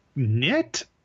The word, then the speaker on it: Gnit: Next up, Theater for a New Audience will present the New York premiere of Will Eno's new play, Knit is the right pronunciation here. It's spelled G-N-I-T, but it's a new play inspired by Henrik Ibsen's Peer Gynt, which is where that name comes from. So I wasn't sure if we're pronouncing the hard G or not, as you do in Gynt.